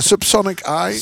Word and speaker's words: Subsonic Eye: Ik vraag, dat dus, uh, zullen ze nooit zo Subsonic, hebben uitgesproken. – Subsonic Eye. – Subsonic dus Eye.